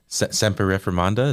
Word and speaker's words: semper reformanda: Semper reformanda, is that right? – That's it. You nailed it.